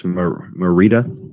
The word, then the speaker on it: Mérida: I believe it's Mérida. – Mérida. We'll go with that. We'll go with that.